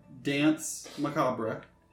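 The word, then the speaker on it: Danse Macabre: I'm sorry, Dance Macabre? – Dance Macabre.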